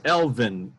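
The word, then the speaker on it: Æ: She said it was Elvin, E-L-V-E-N. Elvin, A-E was Elvin for like intelligence and wisdom, something like that.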